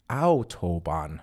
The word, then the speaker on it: Autobahn: Yeah, like when you anytime you have the German letter A and U together, you have to get an ow sound, like owie, I cut myself. Just think of, that's how I used to remember when I first learned German. It's like ouchie. – Ouchie, like Autobahn. So stop saying it wrong, guys.